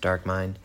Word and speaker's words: DRKMND: p-l-u-t-o and it's by uh, d-r-k-m-n-d which would probably be pronounced dark mind and also Allem Iversom, A L L E M, next name I V E R S O M, Allem Iversom.